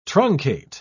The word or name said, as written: truncate